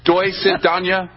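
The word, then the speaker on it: Doi sit danya (до свидания): Doi sit danya. Did I say that right, Mr. Parnas? – Yes.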